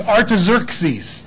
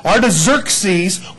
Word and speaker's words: Artaxerxes: The guy that was ruling them at this particular time, and if you can't pronounce that, don't worry about slobbering, his name is Artaxerxes. That's how you say that. Artaxerxes, well, actually, his full title is Artaxerxes Longimanus, but anyway, of Persia, he writes a decree on this date.